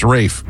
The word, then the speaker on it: Ralph: Pronounced Rafe, even though it's spelled Ralph.